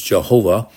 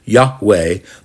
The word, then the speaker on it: Yahweh: The old translations was Jehovah, but that's not the correct pronunciation. It's Yahweh, the God of your fathers, the God of Abraham, the God of Isaac, and the God of Jacob has sent me to you.